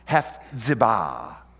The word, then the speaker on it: Hephzibah: You see those two little dots over the left side? That's where your accent is. It should be pronounced Hephzibah.